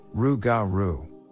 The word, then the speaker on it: Rougarou: the Rougarou, which is pronounced Rougarou.